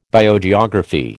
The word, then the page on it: Biogeography: American English